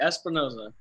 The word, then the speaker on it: Espinoza: That's right, Espinoza.